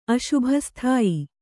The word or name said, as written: ಅಶುಭಸ್ಥಾಯಿ